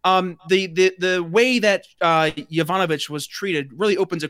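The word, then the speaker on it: Yovanovitch: So there was a Washington Post article, and I forget what day it was, but it was after the Yakunovich. How do you say her name? She was the former Yovanovich.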